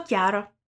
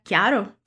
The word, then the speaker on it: chiaro: E você reparou também na pronúncia da palavra chiaro, que significa claro? O que ela tem mesmo de especial? Ela é escrita com CH, assim como meu nome.